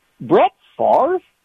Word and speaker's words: Brett Favre: But these, Brett Favre? – Thank you. – You guys said it right.